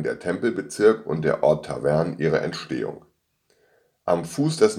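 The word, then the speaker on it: Tawern: Aus dem lateinischen Wort Tabernae bildete sich im Laufe der Zeit der Ortsname Tavern, wobei entgegen der sonst üblichen Aussprache bei ähnlichen Ortsnamen im Deutschen die Betonung auf der zweiten Silbe des Namens liegt und so auch heute noch auf den römischen Ursprung unseres Ortes hinweist. Von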